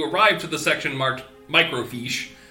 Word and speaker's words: microfiche: That's microfiche? That's how it's pronounced. – Yeah. – It sounds like you're saying it like microfish, but with like a European accent.